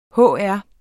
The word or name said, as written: hr.